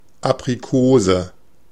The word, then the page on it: Aprikose: /ˌapriˈkoːzə/